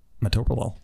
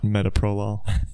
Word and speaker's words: metoprolol: How do you say metoprolol? – Metoprolol. – Okay. Not metoprolol.